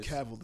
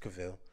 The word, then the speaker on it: Cavill: Oh, we pronounced his name Cavill this whole time. – It's Cavill. like Deville. Cavill.